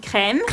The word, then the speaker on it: Creme: How do you pronounce that? – Creme. – Creme. Nivea Creme.